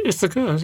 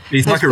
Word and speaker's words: Ithaca: Well, a film, Ithaca, have I pronounced that right? – Ithaca. – Yes, that's right. Ithaca is a destination.